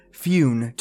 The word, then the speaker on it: Fionn: Fionn, F I O N N, pronounced fune to rhyme with tune, got his first training among women.